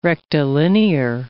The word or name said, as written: rectilinear